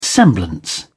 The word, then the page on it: semblance: British English